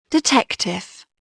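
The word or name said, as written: detective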